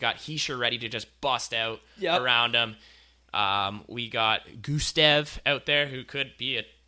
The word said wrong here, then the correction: Gusev: Is that how you say his name? – Gusev. – Gusev. There we go. – My apologies. You just added a T.